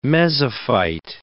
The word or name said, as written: mesophyte